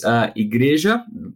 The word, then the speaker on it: Igreja: And I first of all, help me to pronounce the name of your church in Portuguese correctly. – All right. It's Igreja, church, right? Plenitude Cristã.